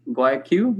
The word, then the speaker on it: Guayaquil: Guayaquil. Did I pronounce it right? – That's correct. Yeah, yeah.